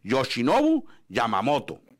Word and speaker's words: Yoshinobu Yamamoto: O sea, las palabras, las letras del japonés y el español suenan igual. No hay que cambiar como en el inglés. – Aquí se lee Yoshinobu Yamamoto. Sí, como en, allá en Europa puede, por ejemplo, los croatas, cómo se escribe y cómo se pronuncian, los alemanes.